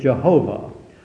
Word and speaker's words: Jehovah: His name is Jehovah, or that's how some pronounce it. Others would say we really don't know how to pronounce it. It's Yahweh, or the scholars don't seem to really know, and if they don't know, I'm sure I don't know.